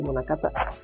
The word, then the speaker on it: Munakata: Taka y Munakata. Sí, así, suena a trabalenguas. Pero así es como se. Es como se pronuncia.